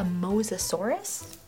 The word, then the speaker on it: Mosasaurus: A Mosasaurus? Am I saying it right? – Yeah.